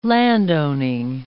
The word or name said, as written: landowning